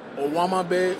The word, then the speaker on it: Owamagbe: Owamabe Odigizua. – Can you spell that for us? – O-W-A. M-A-G-B-E, that's the first name.